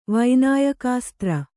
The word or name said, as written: ವೈನಾಯಕಾಸ್ತ್ರ